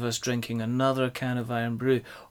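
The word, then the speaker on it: Irn-Bru: There's a traditional Scottish drink called Iron Brew, which is spelled IRNBRU.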